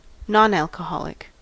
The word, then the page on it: nonalcoholic: American English